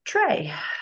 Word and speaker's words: Trai: And it's actually pronounced Trey.